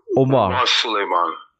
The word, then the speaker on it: Omar Suleiman: Omar Suleiman, Solomon, how do you say it? Omar Suleiman, is that correct? – Omar Suleiman.